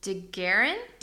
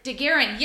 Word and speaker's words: DeGuerin: D-E-G-U-E-R-I-N. – Daguerrean? – Daguerrean, yes, that's it.